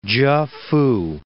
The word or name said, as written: Chefoo